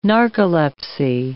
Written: narcolepsy

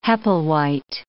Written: Hepplewhite

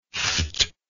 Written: pfft

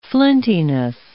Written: flintiness

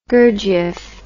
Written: gurdjieff

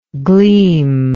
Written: gleamless

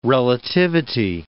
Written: relativity

